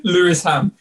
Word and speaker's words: Lewisham: Lewisham.